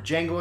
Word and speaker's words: Django: The D is silent. The N word is not. – Oh. – That's the tagline for Django Unchained.